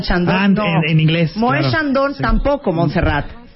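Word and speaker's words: Moët Chandon: Di cómo se pronuncia propiamente. Mueet Shandong. – de Shandong. – Moët Shandong.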